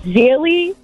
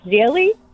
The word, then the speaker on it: Gigli: It's Zilly, or it's spelled Giggly, G-I-G-L-I, but I think it's Zilly.